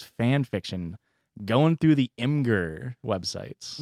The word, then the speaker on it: Imgur: Imgur.